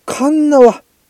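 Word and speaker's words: 鉄輪: で、あ、カンナワ、カンナワね。あ、これでカンナワって読むんだ。鉄に、漢字で鉄に縄で、カンナワ温泉。へえ、こんなんあんだ、九州に。へえ、もみて。え、もみて？もみてでいいのか、これ。あはははは、って、あ、笑ってくれてるんだよね。カンナワで近い。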